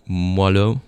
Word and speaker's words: moelleux: I would love to have him have that for the record on how to pronounce moelleux.